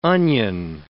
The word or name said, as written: onion